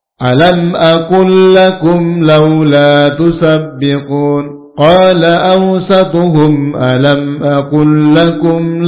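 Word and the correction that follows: Tusabbihun (تُسَبِّحُونَ): Tusabbihun, ba harokatnya ada tasdid, menunjukkan dua huruf.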